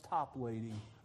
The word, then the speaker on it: Toplady: I went on Google, how do you pronounce his name? He's British. He's from England. Is there some special? No, it's Toplady, Augustus Toplady.